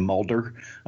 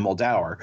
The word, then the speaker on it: Muldaur: But some of the voice talent doesn't know how to pronounce certain names, like when they're talking about Diana Muldaur, one of the readers pronounces her last name Mulder. I'm like, no, it's not Scully and it's, you know, it's Diana Muldaur.